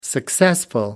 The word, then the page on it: successful: American English